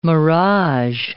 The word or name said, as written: mirage